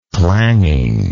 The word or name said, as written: clanging